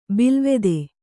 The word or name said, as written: ಬಿಲ್ಲವತ್ತೆ